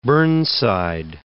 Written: Burnside

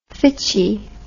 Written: fitchy

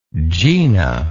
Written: gena

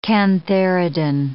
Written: cantharidin

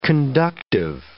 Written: conductive

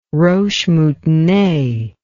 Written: roche moutonnee

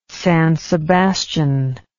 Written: San Sebastian

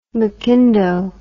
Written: mcindoe

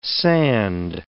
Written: sand

